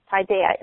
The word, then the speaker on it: paideia: Paideia, pronounced paideia in the Greek, meaning to encompass everything.